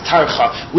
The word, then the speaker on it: Tarcha: The Nagina on the word Hashem, he calls it a Tircha, or a Tarcha, I think it's pronounced Tarcha. We call this a Tipcha.